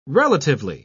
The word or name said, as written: relatively